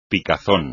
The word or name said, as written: picazón